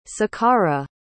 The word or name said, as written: sakkara